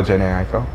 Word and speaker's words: Jhené Aiko: Oh, Jhené Aiko.